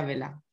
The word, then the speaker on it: Wela: Mónica Vela, con W y una L. Se escribe así, pero se pronuncia Vela.